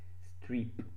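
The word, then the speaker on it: Streep: da Mary Stripper. Strip, no, Strip. Hm, ho sbagliato a pronunciare, quindi sembrava una stripper.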